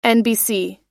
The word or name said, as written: nbc